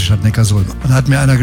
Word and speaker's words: Neckarsulm: Habe ich das richtig ausgesprochen? – Nee, ich glaube, das heißt Neckarsulm. – Ja, und warum? – Ja, weil es in der Datenbank steht. – Nee. Die heißt deshalb Neckarsulm, weil Ulm liegt an der Donau.